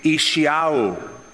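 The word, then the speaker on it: Yeshayahu: His Hebrew pronunciation would be Ishiahu. So you can say that when you go home. Tonight we studied the book of Ishiahu, and that's the book of Isaiah.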